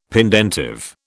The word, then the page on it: pendentive: British English